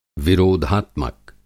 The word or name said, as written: विरोधात्मक